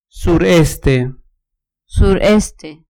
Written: sureste

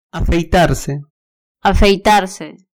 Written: afeitarse

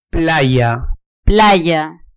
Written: playa

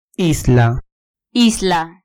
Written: isla

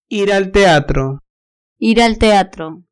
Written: ir al teatro

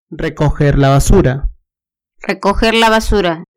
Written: recoger la basura

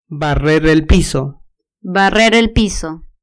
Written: barrer el piso

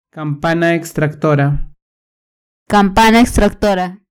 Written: campana extractora

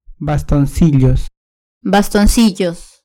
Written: bastoncillos

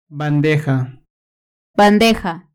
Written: bandeja

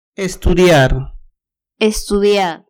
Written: estudiar

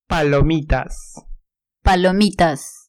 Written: palomitas

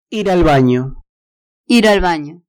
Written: ir al banio

